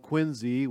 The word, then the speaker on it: Quincy: But when we lived in Massachusetts, we lived in this town called Quincy with a Z. There's other cities spelled the same way that are called Quincy with a C, but this is Quincy in Massachusetts, and it's right there on Wollaston Beach, right next to the ocean.